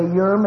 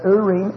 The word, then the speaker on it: Urim: Some people pronounce them with an English pronunciation and say Urim and Thummim. But actually, they're Hebrew words, and they're Urim Thummim.